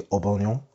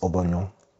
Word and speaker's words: Aubameyang: If you don't support Arsenal, Pierre-Emerick Aubameyang, although apparently it's actually Aubameyang. It's how it's meant to be pronounced, Aubameyang. UEFA every year release pronunciations for players' names, and apparently his name is actually Aubameyang, but Aubameyang.